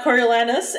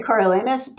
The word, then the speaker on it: Coriolanus: Coriolanus and Julius Caesar. Please correct my pronunciation. – Well, I always say Coriolanus, but I'm sure that varies.